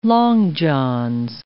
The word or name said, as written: long johns